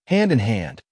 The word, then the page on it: hand in hand: American English